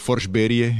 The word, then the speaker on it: Forsberg: Ja som bol nadšený z Patrika Roya, alebo Patrika Roa, ktorý som zbieral kartičky a Joe Sekig a Peter Forsberg, alebo Forsberie, jak to radi Švedi rozprávajú v ich výslovnosti.